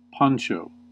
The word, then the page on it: poncho: American English